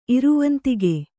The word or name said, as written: ಇರವಂತಿಗೆ